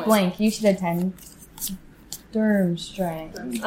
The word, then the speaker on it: Durmstrang: Durmstrang. – Durmstrang. Yeah, yeah. I was like, that's how you pronounce it, right? – Yeah. – Okay, we're good? – Yeah, you're good.